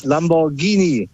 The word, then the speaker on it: Lamborghini: Genau, GH ist Lamborghini.